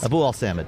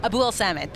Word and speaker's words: Abuelsamid: You pronounce it, please. – Abuelsamid. – Abuelsamid. Thank you.